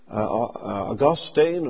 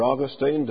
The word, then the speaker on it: Augustine: Now, in opposition to that, there is what's called Augustinianism, named after Augustine, or Augustine, depending on how you pronounce his name.